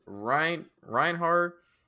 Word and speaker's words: Reinhard: Okay, so first of all, Julius and Reinhardt? How do you say his name? – I think it's Reinhard. – Reinhard. I don't think there's a D.